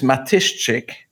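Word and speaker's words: Matyszczyk: actual pronunciation is Matishchik uh, because the SZ and the CZ in the middle are like SHCH.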